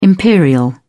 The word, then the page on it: imperial: British English